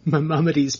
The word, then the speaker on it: Maimonides: Maimonides Park, the second they got out of there.